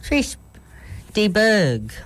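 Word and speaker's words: Chris de Burgh: Oh, I'm saying it wrong. Chris DeBerg with the Don't Pay the Ferryman.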